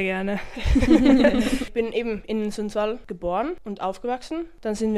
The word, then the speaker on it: Sundsvall: Du bist in Schweden aufgewachsen, in Sundsvall, habe ich das richtig ausgesprochen, ja.